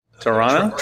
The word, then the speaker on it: Toronto: Toronto? – Toronto.